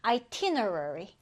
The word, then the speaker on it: itinerary: 이 단어 발음이 어려워요. 자, 일단 강세 표시 이렇게 1강세, 2강세, 강세 표시하고 읽어볼게요. itinerary, itinerary인데요.